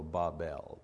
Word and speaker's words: Babel: You know, there's a million ways to say it, but I've looked at the phonetics of it, you know, and the phonetics say the pronunciation should be B-A-W-B-E-L, so Babel, Babel.